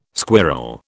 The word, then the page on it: squirl: British English